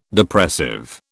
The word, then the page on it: Depressive: British English